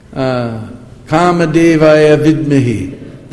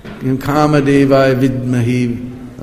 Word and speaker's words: Kama Devaya Vidmahi: And she quoted some grammar book which describes that it goes Kama Devaya Vidmahi, that ya before the vi, the syllable vi becomes a half syllable. Kama Devaya Vidmahi, like that.